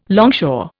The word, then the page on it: longshore: Indian